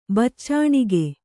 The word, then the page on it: ಬಾಚಣಿಗೆ: bācaṇige